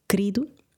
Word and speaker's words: querido: But we probably would pronounce it querido. – Yes. – As if it was with a K or C, R-I-D-O. Querido.